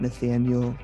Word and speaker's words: Nathanial: but you can find me on Twitter at Nathanial T. Green. Nathanial is spelled with an I-A-L at the end, and that's a whole story unto itself.